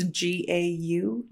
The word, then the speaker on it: Gaughen: It's Gaughen.